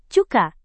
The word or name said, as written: cuka